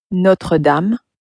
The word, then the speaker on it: Notre Dame: Now, most of us have heard of the cathedral that's in Paris, Notre Dame, which in French is, of course, pronounced as... – Notre Dame. Notre Dame.